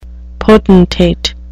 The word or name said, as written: potentate